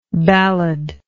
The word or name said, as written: balladlike